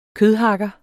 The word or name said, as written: kødhakker